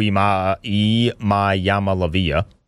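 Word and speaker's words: Iamaleava: Nico Imayamalavia.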